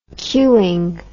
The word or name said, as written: cueing